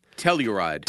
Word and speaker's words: Telluride: How do I pronounce the first one? Telluride? – Telluride.